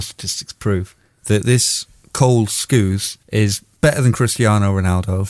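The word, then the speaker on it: Skuse: It's spelled nothing like it sounds because I'm pronouncing it wrong. Skews, Cole Skews. Sorry, I was giving you an extra R. It's S-K-U-S-E, which I didn't even think's a name for a human animal, but there he is.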